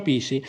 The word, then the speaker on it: PSSI: Io ho fondato il mio partito, il partito super sovranista italiano, PSSI, si pronuncia Pissi.